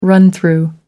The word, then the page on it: run through: American English